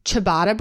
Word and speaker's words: ciabatta: Anyway, she had this recipe and it was ciabatta bread. Is that how you say it? A ciabatta roll. – That sounds right.